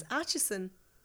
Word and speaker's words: Acheson: It's Acheson.